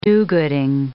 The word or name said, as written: do-gooding